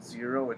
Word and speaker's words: Xero: We use a software called Xero. It's spelled X-E-R-O.